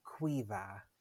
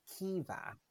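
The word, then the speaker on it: Caoimhe: We don't have the letter K in Irish, so our C's are pronounced qu, qu. A-O-I is pronounced E. And M-H's are pronounced V, like V's. So that's pronounced Quiva, Quiva. – Quiva. – Some girls pronounce it Kiva, Kiva. Two pronunciations, neither of them suggested by you.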